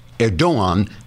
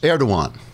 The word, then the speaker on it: Erdoğan: pronounced Erdoğan because the G is silent, as in angster.